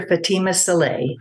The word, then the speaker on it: Fatimah Salleh: She's Reverend Dr. Fatimah Salleh.